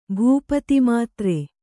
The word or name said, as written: ಭೂಪತಿಮಾತ್ರೆ